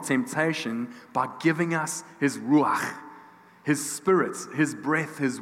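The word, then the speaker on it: ruach (רוח): His ruach. And we heard that word last week, which Paul taught us to pronounce his ruach.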